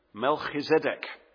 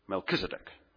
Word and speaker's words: Melchizedek: The second king is the king of Salem, Melchizedek. That's a better pronunciation, by the way, than Melchizedek, which is a very English, anglicized way of saying Melchizedek. Melchizedek is probably truer to the Hebrew.